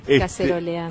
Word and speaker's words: caceroleando: Ayer estuve cacerolando. – Caceroleando. – ¿Cómo es? ¿Cómo se dice? – Caceroleando. – Caceroleando.